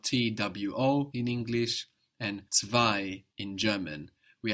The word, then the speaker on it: zwei: Number two in German is zwei. – Zwei. – Zwei. Zwei is spelled Z-W-E-I. We've already seen how the Z in German is pronounced T-S.